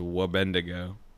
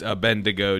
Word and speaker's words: Abednego: Someone's been saying it on the subreddit as a Wabendigo, but I want to clear it up right now. It's Abednego, dude, or whatever.